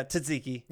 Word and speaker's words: tzatziki: I know I mispronounced it, right? – Yeah, tzatziki.